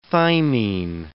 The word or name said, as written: thymine